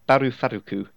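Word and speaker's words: Baru Faruku (バルファルク): Baru Faruku is how it's pronounced in Japanese, but apparently that's based on the English words. Valor and Falcon, so everyone uses the English name of Valphalk.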